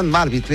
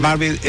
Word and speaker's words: Marwitz: Hans Marwitz, está bien. – Hans Marwitz, M-A-R-W-I-T-Z.